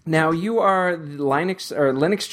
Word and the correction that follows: Linux: Journal's, wow, I mispronounced Linux, Linux Journal's webmistress, and you're the resident Drupal nerd of Linux Journal? – That would be me, yeah.